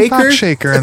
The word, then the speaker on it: Tupac Shakur: Tupac Shakur in this.